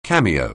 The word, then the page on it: cameo: anglais